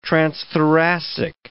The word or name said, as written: transthoracic